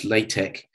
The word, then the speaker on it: LaTeX: LaTeX, pronounced LaTeX. The capital L small a, capital T small e, capital Greek chi.